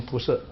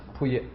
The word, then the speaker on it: 仆射: 但是你们呢，最好不要读成铺设啊，铺业。